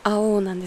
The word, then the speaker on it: AOU: 曲があって、曲自体はもうすでに完成してあるんですけど、レコーディングをこれからっていう曲がいくつかあって、でちょっと前に 1 ヶ月ぐらい前かな、アオーっていうこのアルバムのタイトルアオーなんですけど、このアルバムのタイトル曲となっているアオーっていう AOU でアオーって読むんですけど、アオーっていう曲があるんですね。